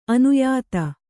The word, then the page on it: ಅನ್ಯಥಾ: kannada